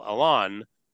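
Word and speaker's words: Allon: And former Israeli foreign minister Yigal Alon definitely pronounced his last name not like Alan.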